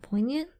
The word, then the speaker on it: poignant: She was poignant. Is it how you pronounce that? – Poignant? – Poignant. I said poignant.